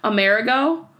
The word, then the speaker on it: Amerigo: So, Amerigo? – Like a merry-go-round? – Oh. It's spelled like America, Ameri, okay. And then go. And it's one word. Amerigo.